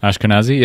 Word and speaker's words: Ashkenazi: Ashkenazi.